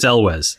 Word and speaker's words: Elwes: That's how you pronounce it? – That's what I looked up today, because I was like, I have always pronounced this incorrectly. – I've always been like, Elwes. I believe it's Elwes.